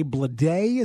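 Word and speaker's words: Bleday: There's J.J. Bladé, is that how you pronounce it? – Yep.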